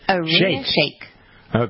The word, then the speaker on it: Irina Shayk: Irina Shayk.